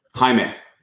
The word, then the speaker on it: Jaime: Jaime.